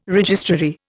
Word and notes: registrary: Indian

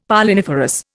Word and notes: Polliniferous: Indian

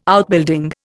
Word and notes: Outbuilding: Indian

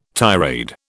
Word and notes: tirade: British English

